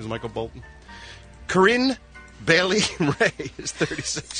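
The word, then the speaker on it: Corinne Bailey Rae: But I said it right, Corinne Bailey Ray.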